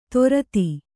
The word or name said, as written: ದೋರ್ದಿ